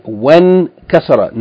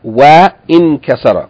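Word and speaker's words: وانكسر: So we just say when kasara, not wa in kasara.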